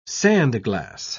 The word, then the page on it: sandglass: British English